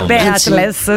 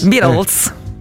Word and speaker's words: Beatles: Beatles, sicuro. – Noi ci fidiamo. La pronuncia male. – Qual è la pronuncia esatta dei Beatles? – Beatles.